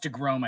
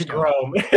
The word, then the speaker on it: deGrom: It's pronounced DeGrom, I believe, but yes. – DeGrom.